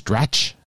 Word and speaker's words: Dratch: Stan is voiced by Rachel, I think it's Dratch is her name, or the pronunciation of her last name. – Yep, that's it.